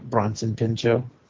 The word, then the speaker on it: Bronson Pinchot: Bronson Pinchot.